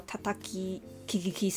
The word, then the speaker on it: 田滝ききき: 那所以那个原作的话，那个名字应该是念作 Tataki Kikiki 桑。